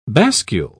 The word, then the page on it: bascule: British English